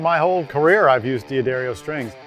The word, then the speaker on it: D'Addario: I've been with D'Addario my whole career, even when I was playing bass in Van Halen, because that's what Dad would play, D'Addario for life. And I say it right, D'Addario.